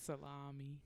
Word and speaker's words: salami: That's not how you say it. Please don't disgrace me like that. – It's salami. Salami.